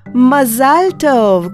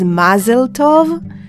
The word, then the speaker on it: Mazal Tov: And also you can come and say Mazal Tov, congratulations. Yes, you do not pronounce it as Mazel Tov. No, you pronounce it Mazal Tov. Mazal is luck or fortune. Tov is good. So, Mazal Tov.